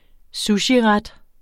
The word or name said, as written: sushiret